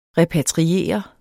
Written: repatriere

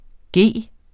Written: g

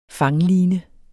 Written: fangline

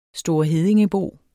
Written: storeheddingebo

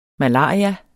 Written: malaria

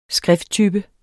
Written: skrifttype

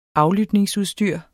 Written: aflytningsudstyr

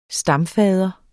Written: stamfader

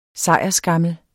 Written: sejrsskammel

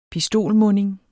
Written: pistolmunding

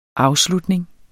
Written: afslutning

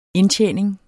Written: indtjening

